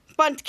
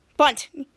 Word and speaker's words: bundt: Henry said, well, I like cake, but and Catcher Henry said, bundt cake, bundt. Henry said, right, bundt. Catcher Henry going next to Miss Hendricks going next to Henry's, and there's a silent D in it. B-U-N-D-T. There's a bunt.